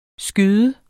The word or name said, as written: skyder